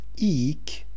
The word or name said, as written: ik